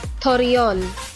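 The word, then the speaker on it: Torreón: The longest duration of totality will be 4 minutes, 28 seconds near, how do you pronounce that? Torreon, Mexico? – Torreon, Mexico. – Torreon, Mexico. Okay.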